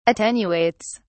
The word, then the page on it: attenuates: British English